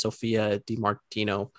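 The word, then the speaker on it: Sophia Di Martino: And also, I think I butchered her name last episode, but her name is Sophia DiMartino.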